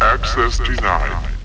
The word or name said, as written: Access denied